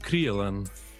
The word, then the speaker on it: Krylon: It's pronounced Krylon. Oh, okay. It's a German brand.